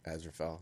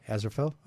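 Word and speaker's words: Aziraphale: Asaphel. Am I saying that correctly? – I do not have to see it. – It'd be from it's Azirapale. – Azirafel, yeah. – Azirafel? Oh, cool.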